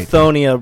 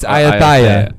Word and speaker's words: Ayutthaya: I think it is Ayutthaya. – It's Ayutthaya. – Ayutthaya.